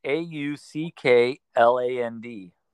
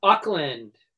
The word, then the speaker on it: Auckland: It's A U C K L A N D. – Auckland.